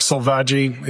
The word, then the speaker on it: Selvaggi: Sure, it's Mark Selvaggi. It's S-E-L-V-A-G-G-I.